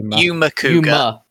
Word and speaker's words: Yuma Kuga: It's like, there's one point where when Yuga first shows... Is that his name, Yuga? Am I saying that right? The new guy? – Yuma Kuga. – Yuma Kuga, right, sorry.